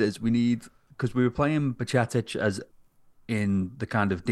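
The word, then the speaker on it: Bajčetić: So you're left now with, obviously, McAllister and Henderson and the young lad who I always butchered the name of. – Bacicic, that's the one. – Yeah. Or Bacicic, you know.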